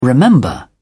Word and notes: remember: British English

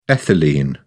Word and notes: ethylene: British English

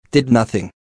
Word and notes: did nothing: American English

